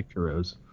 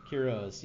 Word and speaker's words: Quiroz: Trevor Shackles asks us, looking at Esteban Quiroz, I'm not sure how to say, what's the right way to say it. – That? Is that? – I would say Quiroz. – Quiroz, yeah.